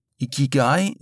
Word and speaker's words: Ikigai: Ikigai wird so ausgesprochen, wie es geschrieben wird.